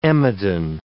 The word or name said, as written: emodin